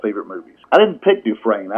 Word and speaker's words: Dufresne: Dufresne, okay. – Andy Dufresne.